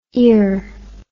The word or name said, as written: iour